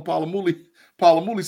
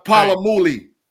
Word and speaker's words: Polamalu: Tim Lyons, you get on the bench because I've been calling Polamalu since we was little kids. so you get to the bench. Polamalu. That's the Polamalu.